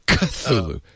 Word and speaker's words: Cthulhu: Lovecraft transcribed the pronunciation of Cthulhu as K-H-L-U-L-H-L-O-O. It's said that the first syllable is pronounced gutturally and very thickly. I'm sorry, I put the emphasis on the wrong syllable.